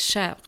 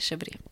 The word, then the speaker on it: chèvre: Och nu blev det ju som press på mig att jag ska kunna någon typ av franskt uttal, men chèvre är ju det rätta uttalet, inte chèvre.